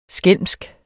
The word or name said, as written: skælmsk